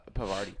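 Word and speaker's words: Parvati: Pavarti. – Yeah, I was Pavarti? About to say Pavarti twins. – I pronounced that weird.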